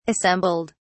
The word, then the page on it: assembled: British English